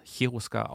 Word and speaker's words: Cherusker: Also die kannten nur Stammesnamen, wie zum Beispiel Sweben oder Cherusker. Kann man auch Cherusker aussprechen, geht beides.